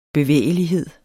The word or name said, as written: bevægelighed